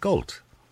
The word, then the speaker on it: Gault: Is it Gault? – It is Gault, yeah.